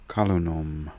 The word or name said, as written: Kalunom